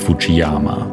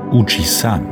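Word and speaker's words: Uchi-san (富士山): In Japan sagt übrigens niemand Fujiyama. Hier ist er bekannt als Uchi-san, wobei das F fast wie ein H ausgesprochen wird.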